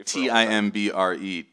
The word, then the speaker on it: timbre: T I M B R E, timbre, like the French would say it